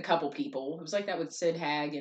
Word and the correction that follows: Haig: and or Haig. Sorry, I mispronounced that.